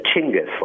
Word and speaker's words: Chinggis: The way it's pronounced in Mongolia is Chinggis, like C-H. – Chinggis. – But, okay. – Yeah, but we got to kind of the way that we do, changing it that's more comfortable for us to pronounce. And, in the Middle East, it's Genghis. So I think he would answer to any name.